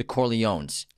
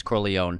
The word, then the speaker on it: Corleone: And also, The name Corleone is pronounced multiple different ways in the Godfather trilogy. Yeah. So when they're talking about Italy, the town Corleone, but then the family is called the Corleones. – And it's, the American pronunciation is Corleone. – And this is and the Italian is Corleone.